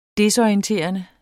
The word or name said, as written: desorienterende